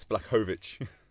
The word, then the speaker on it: Blachowicz: No, we figured it out. It's Blachowicz. – Blachowicz. – Blachowicz. – No, because he's not Russian. That's He's Polish.